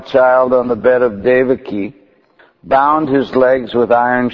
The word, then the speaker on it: Devaki: Yeah, it's the proper pronunciation is Devaki. Because the I is long, not the A.